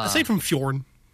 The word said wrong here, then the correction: Fjord: Fjord. Yeah. – You're going to have to say it like Swedish Chef.